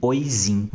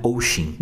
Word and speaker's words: Oisin: Oshin se escreve O-I-S-I-N, Oizin, mas se pronuncia Oshin.